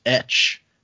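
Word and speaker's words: Etsch: First email is from, I'm going to say this is pronounced Etch. It's E-T-S-C-H.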